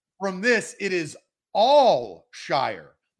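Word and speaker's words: Al-Shaair: he's saying it's Al Shire. Austin, how are you pronouncing his last name? – It's Al Shire. – Well, I will put out the phonetic spelling of what they said. I believe it is Al Shire.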